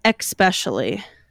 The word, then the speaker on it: especially: You say especially. – Oh, do I? – That is not how the word is pronounced. There's no X in there.